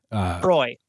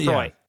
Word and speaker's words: Croix: Is it Croix? – I think it might be Croix. Yeah. And I didn't want to say it because I thought that would be wrong. – I think that's right, because I mispronounce it all the time if it's the same spelling. – Croy. – Yeah. Croy. Yeah, because because I always look at it, I'm like, LaCroix?